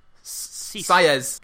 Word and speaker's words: sies: S-I-E-S. How would you say that? – S-I-E-S. – Sires.